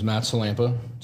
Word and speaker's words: Matt Sillanpaa: Well, my name is Matt Sillanpaa, so you got 1 point already for pronouncing my name correctly.